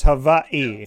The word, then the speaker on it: Hawaii: And I know I said it to you off the air, but when I interviewed Kaylin Ellis, also from Hawaii, I said Hawaii. So I used the W, but you told me that you got to make that W into a V. So I was close, but it's Hawaii.